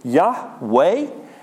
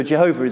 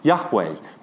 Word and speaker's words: Jehovah: So, Jehovah is actually a mispronunciation. They now think it would be something like Yahweh, but no one knows because the pronunciation wasn't preserved.